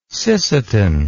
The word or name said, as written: Sisseton